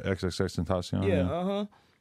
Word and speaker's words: XXXTentacion: Yeah, XXXTentacion.